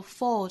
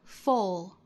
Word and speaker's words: full: I am putting myself to the fullest possible use, 这里 F-U-L-L, full 这个词要区分和 fool 的发音，那 F-O-O-L 表示傻瓜，读成长音紧音的 oo, fool, fool, 单词尾部有舌侧音，舌尖要去找上齿音。那 F-U-U-L 读成 full, full, full, 其中包含的是松元音的 o, o 的发音。